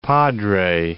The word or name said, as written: Padre Island